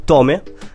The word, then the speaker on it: Tomae: Lui si chiama Tome, voglio chiamare alla latino col littongo. – Ok. Perché si scrive Tomae, sì, però effettivamente Tome.